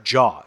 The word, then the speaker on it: Zhao: Chloe, it looks like Zhao, Z-H-A-O. It's actually pronounced Jaw.